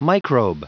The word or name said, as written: microbe